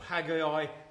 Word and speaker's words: Haggai: Just as we've been gathering around the book of Haggai, or Haggai, depending how you like to pronounce him,